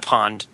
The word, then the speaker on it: Pond: Pond. Did you say Pon? – No, I said Pond.